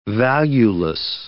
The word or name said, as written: valueless